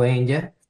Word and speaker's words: Enya: De repente pudiese ser alguien como Sting, o Andrea Bocelli, o Sarah Brightman, o pudiese ser Enya, que bueno, yo nunca supe pronunciar si era Enya o Enya. – Enya, sí.